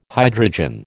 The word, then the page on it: hydrogen: American English